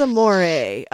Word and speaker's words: Amore: Yes, spelled like moray eel. That's Amore dinner and adult night at the aquarium, which is fabulous.